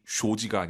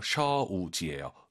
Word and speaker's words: 手机: 要, 学习, 한语, 姐姐, 喝, 카페, 哥哥, 买, 手机, 쇼지가 아니라 오유는 어우예요. 셔우지예요. 手机, 쇼지, 쇼지 하시면 안 됩니다.